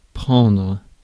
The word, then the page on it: hook: [hʊk]